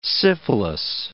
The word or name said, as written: syphilis